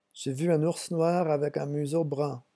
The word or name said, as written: j'ai vu un